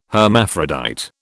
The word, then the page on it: hermaphrodite: British English